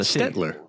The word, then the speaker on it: Stelter: There's an L before the Stetler. – T. No, there's an L before the T.